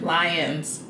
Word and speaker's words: Lyons: When I was in French, like, I had a professor, and she would pronounce my name Lyon. – Yeah. – And it pissed me off. – Why? – Because she'd be like, Mademoiselle Lyon. And I'm like, Lyons.